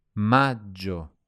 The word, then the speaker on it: maggio: Maggio. Maggio. – Here again, there's a double G pronounced J, which should be nice and long.